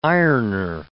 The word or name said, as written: ironer